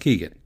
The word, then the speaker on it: Keegan: I noticed when I was editing this episode, when I say Keegan-Michael Key's name, it sounds like I'm saying Keegle-Michael Key, and I absolutely know that his name is pronounced Keegan.